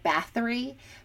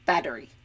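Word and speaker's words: Báthory: So giving that away, my three subjects were Elizabeth Bathory, and most people probably know her as Elizabeth Bathory, but it's pronounced Elizabeth Bathory. It's Hungarian. There is no sound, like the TH sound. It doesn't exist on Hungarian. It kind of sounds more like a D.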